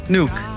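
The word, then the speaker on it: Nuuk: Pronounce for me the town. Is it Nook or is it Nook? – Yeah. Nook. – It is Nook.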